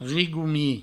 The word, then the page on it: rigomic: rigoumi; Provençal